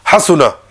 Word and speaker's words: Hasuna: Again, it's got to be quick. Hasuna. – Hasuna.